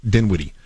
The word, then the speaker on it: Dinwiddie: Dinwiddie uh,